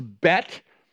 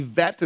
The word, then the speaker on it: bet: Now, I know it says B-E-T-H in your Bibles, but in the Hebrew, that H would be silent. The T-H is just a T sound. So it's bet. Some people actually, if you heard it, they pronounce the B as a V. And so it's not bet, it would be vet.